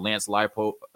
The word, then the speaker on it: Leipold: I want to talk about Lance Leipold. Uh, you know, did I get his name right? – Yep, that's right.